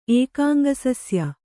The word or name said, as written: ಏಕಾಂಗಸಸ್ಯ